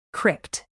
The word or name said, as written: crypt